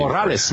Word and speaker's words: Corrales: Corrales, man.